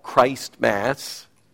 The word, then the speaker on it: Christmas: One is to herald the great message of Christ Mass, as we pronounce it,